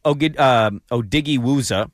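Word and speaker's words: Odighizuwa: They traded for Osa Odighiwuza. – Yes, well done on the pronunciation.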